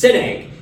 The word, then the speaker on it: Szinegh: Sinek is spelled S-Z-I-N-E-G-H, and it's pronounced Sinek. You think of a big egg with the word sin written in the middle of it.